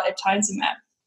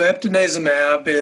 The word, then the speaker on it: eptinezumab: So can you talk to us about eptinezumab? – Sure. So eptinezumab. – I said it wrong. Sorry. Eptinezumab.